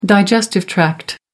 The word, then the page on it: digestive tract: American English